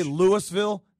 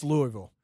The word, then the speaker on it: Louisville: By the way, people that say Louisville, no, it's Louisville. – Well, it's Louisville, right? – It's Louisville.